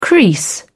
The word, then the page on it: crease: British English